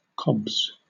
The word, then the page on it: cobs: British English